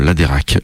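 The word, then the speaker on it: Läderach: Donc si vous allez en Suisse, bah n'achetez pas des chocolats qui seraient signés Laderach. Là, ça s'écrit Laderach. Voilà, je l'ai prononcé à la Suisse. Hein, je me suis entraîné un petit peu.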